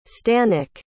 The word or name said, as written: stanek